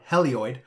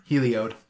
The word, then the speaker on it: Heliod: No, Helioid. – Heliod, sure. – Helioid sounds like some sort of cystic growth that you might have on your undercarriage. – Anyways, so... Heliod. Heliod. – Spell it. – H-E-L-I-O-D.